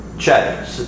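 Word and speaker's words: chay (חי): It's always this word right here, chay. Again, here's how you would spell it, C-H-A-Y.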